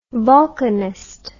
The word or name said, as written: volcanist